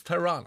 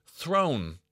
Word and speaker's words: Theron: The truth of the matter is that's not remotely close to the real pronunciation. She revealed that her surname is in fact pronounced Throne, as in Game of, with a slight roll of the R. Throne. Charlize Throne. She also said the name originates in France, which I wouldn't have put a rolling R in.